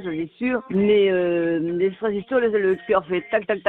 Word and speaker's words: extrasystoles: Alors, Jimmy, les extrasystoles, parce que je crois que c'est comme ça plutôt qu'il faut le prononcer.